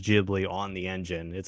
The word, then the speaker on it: Ghibli: So it's Hot Desert Wind, and it's an Italian, in the Italian way, it would pronounce more like Ghibli, but they put the J on it.